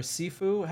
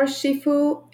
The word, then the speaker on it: Sifu: Sifu or Shifu is either, it's used indistinctively. In the north of China, where both the Sifu are, it's the H is silent.